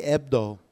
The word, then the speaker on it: Hebdo: You suck at silent H's. Silent H in my last Charlie name. Ebdo.